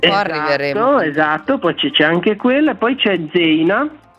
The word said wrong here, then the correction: Zena: Sì, Zena, non Zeina, per cortesia, pronuncialo bene, Zena.